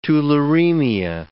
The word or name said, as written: tularemia